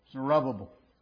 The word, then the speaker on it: Zerubbabel: Zerubbabel.